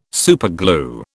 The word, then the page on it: superglue: British English